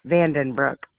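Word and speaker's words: Vandenbrook: No, VANDE as in egg, ENBROOK, Vandenbroek.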